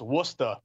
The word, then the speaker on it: Worcester: If you go further west, Worcester, which is pronounced Worcester, Rich, just so you know.